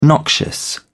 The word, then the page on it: noxious: British English